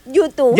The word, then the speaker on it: U2: U2. – Ya sé que se dice U2.